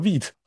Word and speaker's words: Vite: 我说这肯定板上钉钉嘛，V I T E 肯定读 Vite 嘛，然后。然后别人告诉我说不是，这是个法语，所以他读作 wheat 啊，然后就你会觉得很囧，好，好像就是对你的自信心还是挺受打击的，你就开始不太敢，不太敢说。